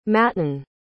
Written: matan

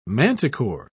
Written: manticore

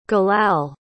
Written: galal

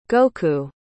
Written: goku

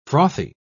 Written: frothy

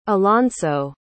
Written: alonso

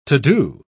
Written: to do

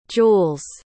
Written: jules

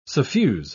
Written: suffuse

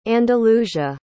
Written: andalusia

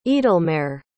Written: eadelmarr

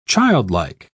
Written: childlike

